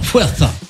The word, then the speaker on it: Fuerza: Est-ce que je le dis bien, Fuerza? – Exactement, Fuerza, puissant, les femmes pour la paix.